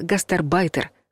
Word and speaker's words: гастарбайтер: Раньше ударение падало на второй слог, но теперь верный вариант такой — «гастарбайтер».